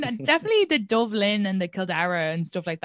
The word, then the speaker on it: Kildare: And Dublin is like Blackpool or like I'm from Kildare, which is Kildara. But when the Brits pronounce it, it's Kildare. So I'd like to think I have like a white trash American spelling of Sean.